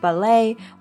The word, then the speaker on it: ballet: Her spare time is often taken up at the Harvard Ballet Company.